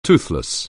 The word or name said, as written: toothless